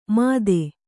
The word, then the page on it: ಮತ್ತೆ: kannada